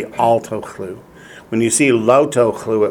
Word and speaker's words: lo tochlu: In Hebrew, that is lo tochlu. Spell it L-O space T-O-C-H-L-U. Lo tochlu.